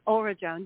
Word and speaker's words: Auradrone: Auradrone, yes, Auradrone.